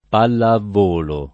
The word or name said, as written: palla a volo